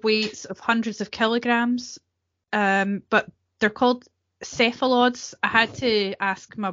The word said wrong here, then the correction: cephalopods: Cephalods? No, now I've confused myself. – Cephalopods.